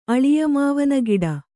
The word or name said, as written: ಅಳಿಯಮಾವನಗಿಡ